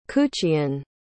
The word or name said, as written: Kuchean